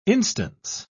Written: instance

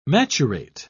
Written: maturate

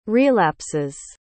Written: relapses

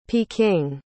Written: peking

